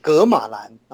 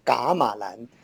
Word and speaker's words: 噶瑪蘭: 那这个噶玛兰呢，有些朋友读作格玛兰啊，正确的读法应该是读作噶玛兰。这个噶字呢，就是我们说呢。” 诸葛亮的葛啊，嗯，然后再加上一个口字边啊，这个字呢读作嘎。